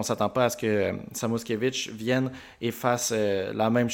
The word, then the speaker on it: Samoskevich: Excusez-moi, je vais me concentrer 2 secondes pour bien prononcer son nom. C'est Samoskevich, un allié droit qui pourrait venir puis tenter un peu de remplir ce trou-là.